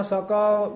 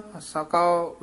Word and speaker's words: सकाओ: मैं मन तन भुख लगाइया जियो, हा रह न सकाओ बिन देखे मेरे प्रीतम। – सकाओ उच्चारण करना सको नहीं कहना, हा रह न सकाओ बिन देखे मेरे प्रीतम।